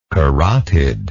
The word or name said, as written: carotidal